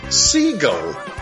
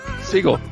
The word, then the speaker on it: Segel: Segel. – Segel.